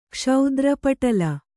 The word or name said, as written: ಕ್ಷೌದ್ರಪಟಲ